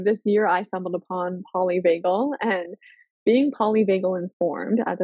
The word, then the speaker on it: polyvagal: I do have one question, and that was the polyvagal.